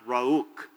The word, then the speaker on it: Ruach: And I think it's pronounced Ruach. And you got a little kuk in there, you know.